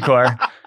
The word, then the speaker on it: Corps: He was like, he's like, well, first off, it's the Marine Corps. And he's like, what are you talking about? And I was like, I thought it was the Marine Corps, but it's spelled corpse. He's like, oh, that's just how they spell it.